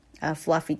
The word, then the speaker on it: fluffy: 是 i 它不是 e 它是 i 有点像 E，有点像 A， with 接下来 fluffy clouds 这里注意一下，它这个两个都被标了中毒因为在整个句子当中说的是一个觉得它像一个 fluffy clouds 所以它这样产生了一个中毒fluffy 这个 U 发的是 R 的音 Fla Fla Fee、啊、这个 Fee 是真正的一个意义上的 E 这个发音啊，不用把它给这个轻化，不是这个 with 这样子处理的 Fluffy、啊、这个 E、发音越清晰越好、